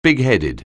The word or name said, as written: bigheaded